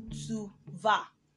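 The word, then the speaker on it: vas: Tu va. The va is pronounced V-A-S. V-A-S. Tu va.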